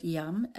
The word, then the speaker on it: Yam: Yam for the heart chakra. Pronounce the word like the word yum, as in yummy.